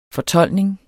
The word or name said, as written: fortoldning